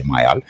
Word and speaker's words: Mayall: Gândește-te că John Mayall, la vremea respectivă, că mă rog, ani de zile am pronunțat John Mayall, dar e Mayall.